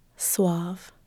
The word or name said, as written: suave